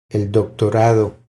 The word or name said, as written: El doctorado